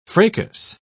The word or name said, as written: fracas